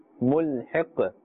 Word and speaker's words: ملحق: This قاف, we are giving it a temporary ساكن or جزم, and therefore we are pronouncing it with the قلقلة, the slight bouncing or echoing sound. ملحق, not too much.